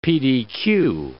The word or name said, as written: PDQ